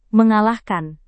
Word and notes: mengalahkan: Indonesian

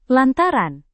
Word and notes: lantaran: indonesian

